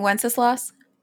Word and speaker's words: Wenceslas: King Worcestershire? What did you just say? – King Wenceslas? – That's how you say that? – That's how you seriously? Pronounce it. That's how you pronounce it.